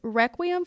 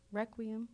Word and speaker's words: Requiem: Requiem for a Dream. Did I say that right? Requiem.